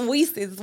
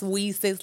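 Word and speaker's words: WiCyS: It's WESIS, Women in Cybersecurity, and it's pronounced WESIS like We Sisters.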